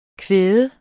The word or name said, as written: kvad